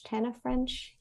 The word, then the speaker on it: Tana French: Tana French? – Yeah.